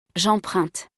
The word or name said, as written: j'emprunte